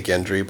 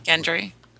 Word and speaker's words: Gendry: I say Gendry, but... – Gendry.